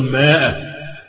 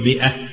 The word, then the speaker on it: مئة: يعني برضه يعني انت فكرتني بشيء لطيف اللي احنا قلناه قبل كده في الاعداد، والله لحد دلوقتي شفت واحد من الكبار وهو يقرا قل ماء، هو طبعا انا شايف الف، هو بيشوف الف مكتوب الف، احنا قلنا ان هذه الالف لا تنطق، قل مئة يعني مئة وليست ماء.